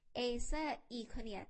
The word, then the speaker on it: Acer Iconia: Acer i c o n i a